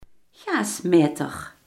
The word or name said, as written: gasmeter